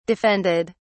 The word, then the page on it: defended: British English